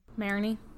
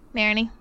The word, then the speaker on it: Marigny: Marigny. – Marigny. Yeah. – Mm-hmm. – Yeah. Okay. – The G is silent.